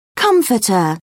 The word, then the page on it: comforter: British English